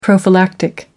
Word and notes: prophylactic: American English

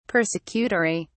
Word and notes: persecutory: British English

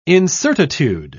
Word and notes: incertitude: British English

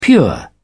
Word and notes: pure: British English

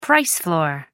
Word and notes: price floor: American English

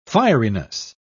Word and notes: fieriness: British English

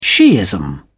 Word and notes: shiism: British English

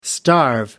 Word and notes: starve: American English

